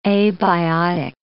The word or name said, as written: abiotic